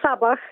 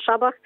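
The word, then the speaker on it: Šabach: E, wielu Polaków czyta go jako Sabach, jakby był jakimś satanistą. No, Sabab, Sabach, on, on się nazywa Szabach. To S z daszkiem czyta się szy.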